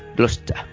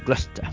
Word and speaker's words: Gloucester: Y también termino por mencionar la catedral de Peterborough, Peterborough se escribe, y la catedral de Gloucester, diríamos en castellano, pero los ingleses dicen Gloucester, así con ese acento británico que tienen, ¿no? Gloucester.